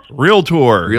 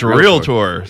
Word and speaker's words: Realtor: I'm going to make it my mission to that is one of the most mispronounced words in the country. Say how you say the association again. – Realtor. – Yeah, Realtor. It's Realtor. So yeah. But it's amazing how many people say that differently.